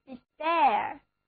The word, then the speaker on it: despair: air 字母组合发作 air, 重音放在第二个音节上，单词就读作 despair, 绝望的。